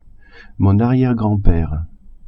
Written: arriere-grand-pere

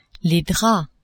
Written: draps

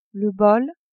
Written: bol

